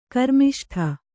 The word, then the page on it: ಕರ್ಮಿಷ್ಠ: karmiṣṭha